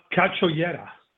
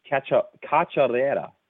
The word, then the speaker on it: Cachoeira: sorry, say her name again, please, in Portuguese. Cachoeira? – Cachoeira. – Cachoeira. – Something like that. – Yes? – Yeah, you're good.